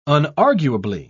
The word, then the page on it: unarguably: British English